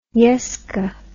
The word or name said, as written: yesca